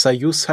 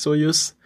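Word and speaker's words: Soyuz (Союз): Also wir wissen ja, dass es eine, dass es Soyuz heißt und nicht Soyuz.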